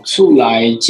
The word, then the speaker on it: 厝內: Hello，谢谢主持人小访，还有 Firas，我是醋来这个生活用品的创办人，大家好。 – 可不可以先跟我们介绍一下醋来这个品牌到底是在卖些什么东西啊？ – 哦，醋来其实它就台语发音的一个品牌，醋来就是台湾话家里面的意思，所以。